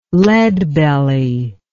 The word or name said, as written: Leadbelly